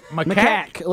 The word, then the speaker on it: macaque: Macaque. Let's go with macaque.